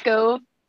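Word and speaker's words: Skove: Scove. Oh yeah, that happens a lot. It's Scove. It's like stove, but with a K instead of a T.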